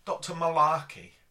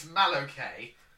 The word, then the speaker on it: Malohkeh: I've written his name, Dr. Malarkey. It's like they just couldn't be bothered. – It's spelt Malokay. It's Malokay. M-A-L-O-H-K-E-H.